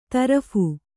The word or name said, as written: ತರ್ಫಿ